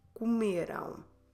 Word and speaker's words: comeram: So our ending now will be E-R-A-M. O, em português, E-R-A-M. So you guys ate, vocês comeram. Comerão. Make sure you close your lips when you finish. Comerão. And stress the second to last syllable, me.